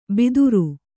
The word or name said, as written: ಬಿದುರು